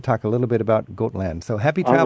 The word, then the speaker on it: Gotland: It's called Gotland.